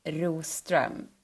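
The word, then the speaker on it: Roström: My name is Anna Rostr. So it's a Swedish name, and I want you to pronounce it for me now. My name is Anna, and listen now, Rostr.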